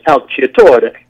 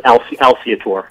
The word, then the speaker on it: Alciatore: Well, it's Italian, so the Italian pronunciation is Alciatore. – Alciatore. – But we pronounce it Alciatore.